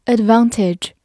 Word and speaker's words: advantage: Advantage, A D V A N T A G E, advantage, A D 发 ad，V A N 发 van，T A G E, tige, ad。 Advantage, advantage，美式发音中间的字母 a 可以发大口 a，advantage 也是可以的。